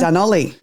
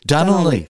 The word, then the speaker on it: Dunolly: Dunolly. – Dunolly.